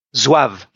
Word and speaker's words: Zouave: But we learn a little bit about, and I'm not sure how you pronounce it, Zouave? – Zouave.